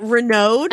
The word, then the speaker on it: Raynaud: He's French. It's Raynaud.